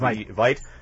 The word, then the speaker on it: Veidt: It starred Conrad Veidt, or Veidt, I'm not sure how it's pronounced, Veidt,